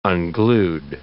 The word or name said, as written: unglued